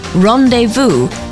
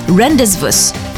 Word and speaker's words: rendezvous: Rendezvous is pronounced rendezvous, not rendezvous.